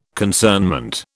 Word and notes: concernment: British English

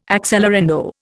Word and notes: accelerando: Indian

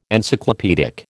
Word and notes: Encyclopaedic: American English

